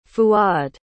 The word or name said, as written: fuad